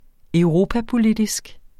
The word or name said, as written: europapolitisk